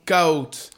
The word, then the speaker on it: Kahoot: C'est la musique de Kaout, c'est ça Exactement, Kaout. Kaout, excuse-moi. Ah, Kaout, comme tu veux. ça s'écrit K-A-H-O-O-T, point d'exclamation.